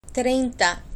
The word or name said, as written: treinta